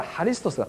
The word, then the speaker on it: ハリストス: とこれはギリシャ語の X は派と読むから、ハリストスが正しいんだと書いてありました。